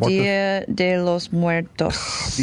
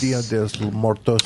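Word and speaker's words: Día de los Muertos: We're going to do a whole Dios de la Muerte. How do you pronounce it? – Dia de los Muertos. – Dia de los Muertos.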